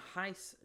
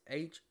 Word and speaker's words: Hesse: I have about 5% from Germany listening to me right now, from Heiss, Germany. If I'm pronouncing that wrong, people from Germany, I am sorry. But it's Hesse, from there.